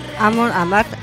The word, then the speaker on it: Amon Amarth: Amon Amarth, se dice así. Amon Amarth. Sí, Amon Amarth.